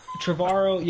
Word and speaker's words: Trevorrow: Trevorrow, yeah.